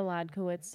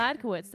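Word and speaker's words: Hladkowicz: It's me, Julia Ladkowitz. Did I say my name already? I can't remember. – Say it again for the people in the back. – Julia Ladkowitz. That's Ladkowitz with an H. H-L-A-D-K-O-W-I-C-Z.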